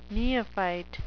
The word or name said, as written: neophyte